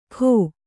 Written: ಖೋ

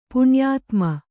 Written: ಪುಣ್ಯಾತ್ಮ

